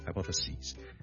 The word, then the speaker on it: hypotheses: 由 sis 转咗做 sees，你当好似系 CSEA 或 SEE 嗰个 C，跟住再加多个 S， so singular hypothesis， plural hypotheses， singular crisis， plural crises。